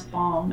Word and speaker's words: balm: I also have a hint of an L when I pronounce balm, as in lip balm, or there is a balm in Gilead. It's not like too. – Balm, but it's there.